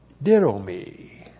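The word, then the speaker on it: didomi (δίδωμι): Didomi. D-I-D, long O-M-I, didomi.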